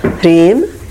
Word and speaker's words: Hrim: Hrim. – Hrim. – H-R-E-E-M is how it sounds.